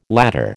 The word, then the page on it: ladder: American English